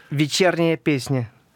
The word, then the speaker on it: Вечерняя песня: Elle se prononce «Вечерняя песня». Exactement, «Evening Song».